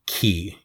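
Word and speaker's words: key (Kingdom Hearts χ): But uh, the game itself was actually originally known as Kingdom Hearts Unchained Key, even though that's written as an X. It's pronounced key, you know, like Keyblade.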